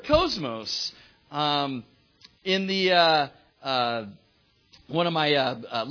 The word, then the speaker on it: cosmos: I got this cool little Greek pronunciation thing that I click on, and he says cosmos.